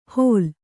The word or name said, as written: ಹೋಲ್